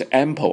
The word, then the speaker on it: ample: 一个是 enough，一个是 ample，不要念成 apple，不是苹果，是 ample 啊，最后一个是 adequate，这三个单词都表示充分的啊，用法一样啊，很简单。